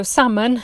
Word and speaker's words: salmon: So, salmon, as you know, is a kind of fish. There's a silent L there.